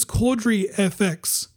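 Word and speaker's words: Cordery: Caudry FX. Now, I'm going to spell it for you. So it's actually a C-O-R-D-E-R-Y space FX.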